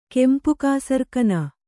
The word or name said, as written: ಕೆಂಪುಕಾಸರಕನ